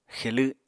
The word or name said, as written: xelw7